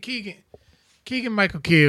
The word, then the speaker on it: Keegan-Michael Key: Well, I mean, Keegan-Michael Key, did I say his name right? – Yeah.